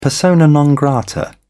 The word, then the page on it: persona non grata: British English